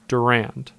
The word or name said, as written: Durand